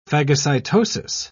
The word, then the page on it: phagocytosis: British English